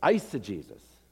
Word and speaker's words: eisegesis: Now, the opposite, the thing we abhor, is pronounced in English, eisegesis, the Greek prefix eis, into, which means I read into the Bible what I want to find there, right?